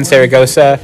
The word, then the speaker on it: Zaragoza: I thought it was pronounced Zaragoza. Is it not pronounced Zaragoza? – Cuando estamos hablando español. – Zaragoza. – Cuando hablo español, digo Zaragoza. Oh, Zaragoza. But when I'm speaking in English, I say Zaragoza.